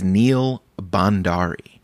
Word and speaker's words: Bhandari: By the way, speaking of which, the last episode with Neil Bhandari, I pronounced his name Neil Bhardwani, and holy shit, that is not his name. It is Neil Bhandari, and I was trying so hard not to get it confused with Neil Bhardwan that I did get it confused, so...